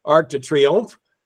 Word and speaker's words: Arc de Triomphe: The French pronounce it Arc de Triomphe.